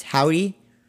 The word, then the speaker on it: Hovvdy: It's H-O-V-V-D-Y, so the two V's just look like just a wider W, I guess. But yeah, it's Howdy, but H-O-V-V-D-Y is how it's spelled.